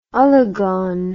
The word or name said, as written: ologoan